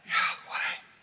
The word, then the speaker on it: YHWH: How do you pronounce that? Yahweh.